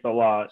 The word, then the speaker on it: Saleh: So that's the proper way of saying Saleh. And so you asked me like, oh, how do you say this guy's name and the coach? And he's an awesome coach, you know, being a Jets fan. It's, you know, I said, this is how you pronounce Saleh.